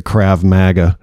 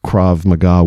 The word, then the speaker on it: Krav Maga: You know, a lot of people say Krav Maga.